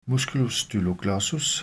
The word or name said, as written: m. styloglossus